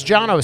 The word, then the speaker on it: Cano: His first name was Jono, C-A-N-O. Yeah, yeah. – With a J. – With a C, but in Armenian, it's pronounced Ch. So it's Jono. So spell his name. – C-A-N-O. – C-A-N-O. Jono. We would say Cano.